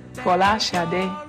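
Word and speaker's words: Folasade: My, my name is uh, my actual my true Nigerian name or the true Nigerian pronunciation or as close as I can get to it is Fola Shade uh, which means crowning glory.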